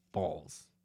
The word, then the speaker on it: Balls: The former mayor pronounced his last name Balls. – Yeah. – How's it spelled? How's it spelled? – B-A-L-L-Z? – It's B-A-L-L-S.